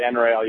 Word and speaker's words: NREL: You got close enough, but pronounced the NREL. You hit the N first and then the REL.